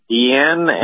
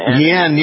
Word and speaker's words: Guillen: Michael Guillen, is that correct? – Well, you know what, Bill? I'm a kid from East Los Angeles, and in Spanish it's Guillen. – Guillen, yes. I know we went through this before.